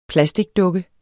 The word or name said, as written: plasticdukke